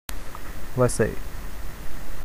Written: Vasai

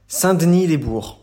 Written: Saint-Denis-lès-Bourg

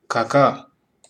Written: Kaká

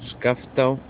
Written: Skaftá

